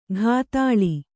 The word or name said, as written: ಘಾತಾಳಿ